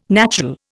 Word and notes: natural: Indian

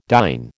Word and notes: dine: American English

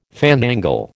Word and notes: fandangle: American English

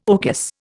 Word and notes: orchis: Indian

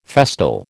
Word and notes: festal: American English

